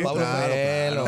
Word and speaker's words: Paulo Coelho: Paulo Coelho, Si lo dije bien? – Claro, Paulo Coelho.